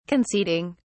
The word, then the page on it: conceding: British English